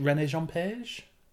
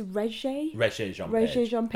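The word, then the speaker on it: Regé-Jean Page: Is it René Jean Page? – Oh, now, I've heard someone say his name as well, and I think it's Regé Jean-Page.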